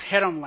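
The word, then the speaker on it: cherem: Pronounce chedem like you're cleaning your throat.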